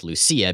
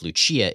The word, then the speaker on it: Lucia: So something I learned about Thorn, first is that her name's probably pronounced Lucia, because Lucia is the Italian pronunciation.